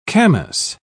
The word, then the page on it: camas: British English